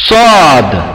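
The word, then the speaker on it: Сад (صاد): Когда произносится эта буква, когда читается, не торопитесь, это немного трудновато. Сад. Вот когда говорите сад, немного надо задерживаться.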